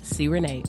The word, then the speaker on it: C-Rena: I'll be like, no, it's C-Rena. I help you pronounce it. C. Renee.